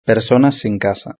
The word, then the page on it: personas sin casa: European Spanish